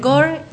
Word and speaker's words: corps: Entre Core y Corp. – Sí. Se debe decir Core. Uh-huh. Y se escribe C-O-R-P-S.